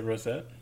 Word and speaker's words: Roset: I don't know if it's Rosette or Rosé. You know if the T is silent? – How's it spelled? Can you just type it in? – R-O-S-E-T. – I would say Rosette.